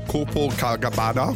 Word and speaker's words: Copacabana: Ey, die ist ja aufgetreten vor kurzem in Rio an der Copacabana. Gott, das ist komplett falsch ausgesprochen. Copacabana. So nämlich.